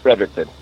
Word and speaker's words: Fredericton: How do you say it? – Fredericton. – Just like yeah. that. Frederick, just like that.